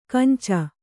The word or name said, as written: ಕೆಂಚು